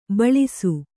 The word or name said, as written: ಭುಲ್ಲೈಸು